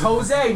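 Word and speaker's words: José: No, it's Jose, man. No, it's spelled J-O-S-E. He pronounces it himself, Jose.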